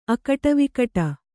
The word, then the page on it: ಅಗಟವಿಗಟ: agaṭavigaṭa